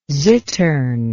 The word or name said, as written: zittern